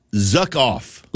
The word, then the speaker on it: Zuck Off: Yes, we have to say it carefully because you can easily swear if you say it wrong. But it's called, with a Z, Zuck Off. – Like, you know, Zuckerberg, the creator of Meta.